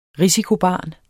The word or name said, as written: risikobarn